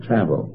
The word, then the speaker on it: travail: Now that word travel, if you look it up in the dictionary, it's spelled T-R-A-V-A-I-L, but it's pronounced travel. I used to call it travail, and then I call it travail, but I find the dictionary says it's travel, and I don't want you to mistake it.